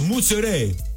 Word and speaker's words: mozzarella: It's rich in antioxidants, fresh mozzarella, or how do they say? – Mozzarella. – They don't even say the A, they go mozzarella.